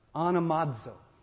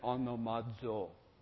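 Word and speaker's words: Onomazo (ὀνομάζω): Now you have to say it like the dude on the internet. Onomazo. See, I figured out to speak Greek, you have to end with that sound no matter all.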